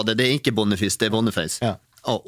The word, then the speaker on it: Boniface: Han är från Nigeria, där uttalas det Boniface.